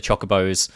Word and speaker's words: chocobo: Then obviously you can get the chocobos, I've always pronounced it chocobos. It's actually chocobo,